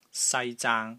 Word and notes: SAI1 ZAANG1: Cantonese

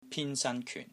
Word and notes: PIN1 SAN1 KYUN4: Cantonese

